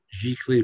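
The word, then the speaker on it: giclée: Well, what I do anyway with the paintings, I sell prints with paintings, and they're what's called G clay prints. It says a posh word. It's spelled GYCLEE, and it just means a special kind of print.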